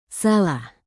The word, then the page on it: salah: indonesian